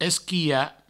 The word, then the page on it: esquilhar: provençal; esquiha